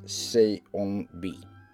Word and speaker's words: Seonbi: I pronounce it wrong, but it is Seonbi.